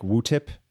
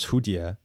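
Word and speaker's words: Hudie (蝴蝶): That is one way to pronounce the two characters it is written in. I would never have known to pronounce those two characters Wutip because that's not a language I know. Right. I would pronounce those two characters Hudie, which means butterfly.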